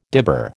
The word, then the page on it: dibber: American English